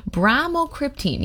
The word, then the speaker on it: bromocriptine: That's bromocriptine.